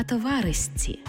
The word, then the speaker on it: товаришці: Наприклад, не «товаришці», а «товаристці». Не «мурашці», а «мурасці».